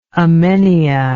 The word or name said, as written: emmenia